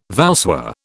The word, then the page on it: voussoir: British English